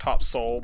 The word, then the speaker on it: Topsail: I guess it's pronounced Top Soul by the people around there, but in like Surf City, North Carolina.